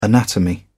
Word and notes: anatomy: British English

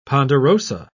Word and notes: ponderosa: British English

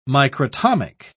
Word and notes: microtomic: British English

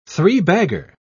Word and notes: three-bagger: British English

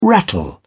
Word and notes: rattle: British English

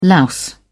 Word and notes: louse: British English

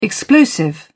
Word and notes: explosive: British English

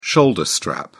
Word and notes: shoulder strap: British English

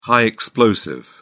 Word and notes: high explosive: British English

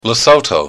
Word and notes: lesotho: American English

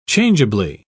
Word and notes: changeably: British English

